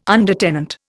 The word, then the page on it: undertenant: Indian